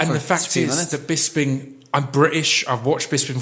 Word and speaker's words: Bisping: Bisping.